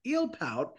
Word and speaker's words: Eelpout: Paul, you got the show Eel Pout. What is that about? I hope I'm saying that right. – No, you're saying it right.